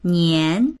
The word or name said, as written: nian2